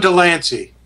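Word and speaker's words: de Lancie: John DeLancey.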